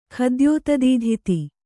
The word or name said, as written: ಖದ್ಯೋತದೀಧಿತಿ